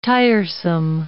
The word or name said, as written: tiresome